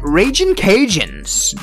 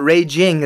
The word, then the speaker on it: Ragin': Not a lot of schools have a nickname, an abbreviated sort of slang nickname, Ragin', it's not Raging, it's the Ragin Cajun, so it rhymes with Cajuns, and I do have to give them a special shout out.